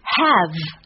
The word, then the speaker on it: have: Have 尾音 e 不发音，D A Y D 的发音是 d，A Y 的发音是 a a a，这个音它的要领在于口型从。